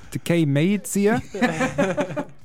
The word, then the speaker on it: Tkay Maidza: I went Tkay Maidza. And as I said it, you text me. Yeah, good job on the pronunciation, bro.